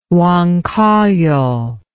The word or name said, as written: Huancayo